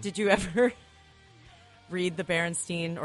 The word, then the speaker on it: Berenstain: Are they Steens or Stains? – I thought it was Berenstain. – I like Berenstain. – It's Berenstain.